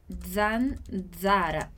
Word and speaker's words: zanzara: Então, a gente vai fazer aquele zzz, e aí a gente vai colocar o quê? Um D na frente. Então, eu vou pronunciar esse som dessa forma. Zan, zara. Zanzara.